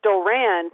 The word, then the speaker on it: Doran: And, Doran Grant, it's Doran, because I watched some of his old interviews at Ohio State, but so maybe that Martavius.